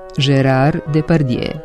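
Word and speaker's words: Gérard Depardieu: Postoji samo jedno rešenje. Gerard Depardieu. Do zabune dovodi to što francuski pravopis u pisanju zadržava neke stare suglasnike ili suglasničke grupe koje se ne izgovaraju u savremenom francuskom jeziku. Naša transkripcija ravna se prema francuskom izgovoru i te glasove ne prenosi.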